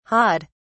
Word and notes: hawed: British English

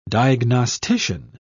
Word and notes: diagnostician: British English

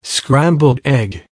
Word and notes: scrambled egg: American English

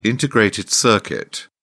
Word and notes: integrated circuit: British English

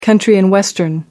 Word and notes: country and western: British English

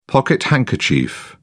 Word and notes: pocket handkerchief: British English

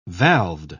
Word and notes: valved: British English

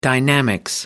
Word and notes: dynamics: American English

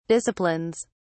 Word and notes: disciplines: British English

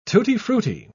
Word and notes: tutti-frutti: British English

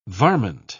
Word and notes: varment: British English

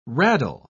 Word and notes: raddle: British English

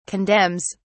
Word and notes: condemns: British English

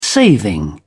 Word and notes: saving: British English

